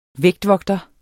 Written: vægtvogter

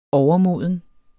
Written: overmoden

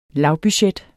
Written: lavbudget

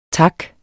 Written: taks

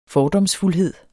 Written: fordomsfuldhed

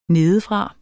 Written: nedefra

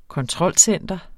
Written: kontrolcenter

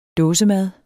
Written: dåsemad

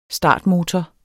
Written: STARTMOTOR

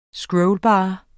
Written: scrollbar

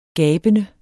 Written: gabende

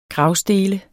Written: gravstele

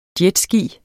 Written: jetski

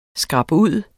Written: skrabud

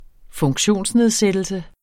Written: funktionsnedsættelse

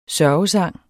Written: sørgesang

